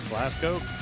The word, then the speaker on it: Glasgow: How do you say it? – Glasgow. – I've never been sure. Like toe the line.